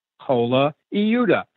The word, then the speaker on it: Colaiuta: Vinnie Caliuta once said to me, C-O-L-A, Cola Iuta, I-U-T-A. Spell it, C-O-L-A, I-U-T-A.